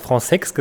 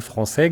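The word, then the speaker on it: Françaix: Du hast Fransex gesagt, das ist auch sehr verbreitet, aber er selber hat wohl immer sich gerne Français genannt und fand das amüsant, wenn man ihn Fransex nennt, weil das so nach altem Adel klingt, wie er sagte.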